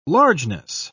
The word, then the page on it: largeness: British English